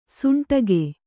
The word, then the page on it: ಸೋಂಟಿಗೆ: sōṇṭige